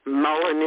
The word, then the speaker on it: mullein: Mullein is not one of them. – Oh, it was in the book. Maybe I'm saying it wrong. M-U-L-L. – No, you're saying it absolutely right.